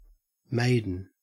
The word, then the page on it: maiden: Australian English